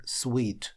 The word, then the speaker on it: suite: И он без буковки «и» в конце этого слова, а вот «и» в конце дает произношение sweet.